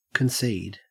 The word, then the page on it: concede: Australian English